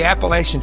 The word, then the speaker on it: Appalachia: There is so much chatter, excitement about the Appalachian Treasure Hunt. Appalachia is how it's pronounced, but it'll go either way.